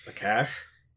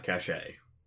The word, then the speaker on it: cache: Like, cachet, like, you know... hidden underneath his bed. – A cache? – Yeah. You find, Because it's pronounced cache, not cachet.